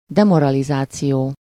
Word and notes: demoralizáció: /ˈdɛmorɒlizaːt͡siʲoː/

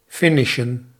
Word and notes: finish: /ˈfɪnɪʃ/

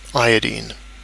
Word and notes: iodine: /ˈaɪ.ədaɪn/; /ˈaɪ.ədiːn/; /ˈai.ə.ˌdain/

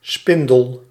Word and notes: arbre: [ɛ̃.n‿aʁ.bʁ]

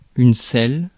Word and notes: selle: [sɛl]; France

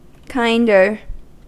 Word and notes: kinder: American English; /ˈkaɪndɚ/

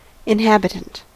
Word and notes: Seele: /ˈzeː.lə/

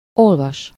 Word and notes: lire: France; /liʁ/

